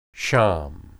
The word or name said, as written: sham